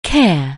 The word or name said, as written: care